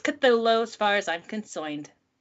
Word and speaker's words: Cthulhu: The actual sound, as nearly as any human organ could imitate it or human letters could record it, may be something like Clulu. The first syllable pronounced gutturally and very thickly. The U is about like that in full, and the first syllable is not unlike the Clul in sound, hence the represents the guttural thickness. Cthulhu. But, you know, we say Cthulhu, because fuck it.